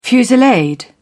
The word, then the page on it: fusillade: British English